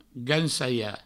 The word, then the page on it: gancalhar: gancaia